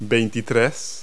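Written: twenty-three

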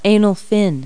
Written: anal fin

